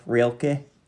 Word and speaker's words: Rilke: Rilke? – That's how they say it in Germany.